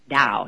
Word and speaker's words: Tao: Geez, you'd think I'd know how to pronounce that. Tao.